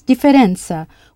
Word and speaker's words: differenza: Yes, differenza, with a stiff double F. And preference? – Preferenza. – Good, preferenza. If città means city, spelled C-I-T-T-A with an accent on the A,